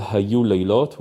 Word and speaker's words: Hayu Laylot: There is a song called "Hayu Laylot," which is uh, my arrangement for a very old Israeli song, so we can hear maybe a bit of it.